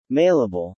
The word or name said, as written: mailable